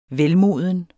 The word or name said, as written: velmoden